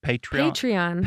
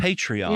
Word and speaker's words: Patreon: Hopefully you follow us into, and you said that I said it wrong, Patreon. – Patreon. – Patreon.